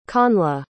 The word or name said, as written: connla